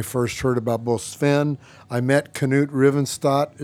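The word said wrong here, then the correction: Reiersrud: Is that how you say his name? – Yeah, Knut Reyersrud, yeah, from yeah.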